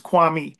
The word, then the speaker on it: Kwame: So Kwame, the original pronunciation of the name is Kwame, and Kwame means born on Saturday.